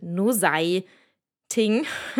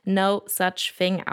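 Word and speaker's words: Nosaj Thing: Äh, Achtung, der Name wird ein bisschen anders geschrieben, als man ihn ausspricht. Äh, man würde es jetzt auf Deutsch vielleicht No Sei Ting lesen. Ähm, etwas verwirrend, aber genau, spricht sich No Such Thing aus.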